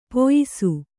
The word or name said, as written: ಪಯಸ್ಸು